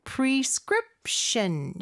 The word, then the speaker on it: prescription: 그리고 마지막에 prescription 여기는 script 요 부분에 강세가 있죠. prescription 내에서 이음절에 강세가 있는 거 요거 기억하시면서 우리 큰 목소리로 따라해 볼게요.